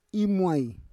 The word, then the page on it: imuai: Roon